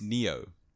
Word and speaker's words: Nioh: It's not no. Almost, it's Neo.